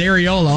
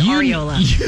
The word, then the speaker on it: Areola: When you've got a player who's as talented as Areola on your team. – Stop it. It's Areola.